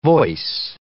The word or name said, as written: voice